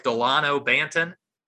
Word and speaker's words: Delano Banton: Delano Banton.